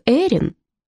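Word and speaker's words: Irene: «Ну как же, ты называешь меня Айрине, а ведь, согласно американским правилам, мое имя произносится как Эрин».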